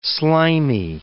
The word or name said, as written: slimy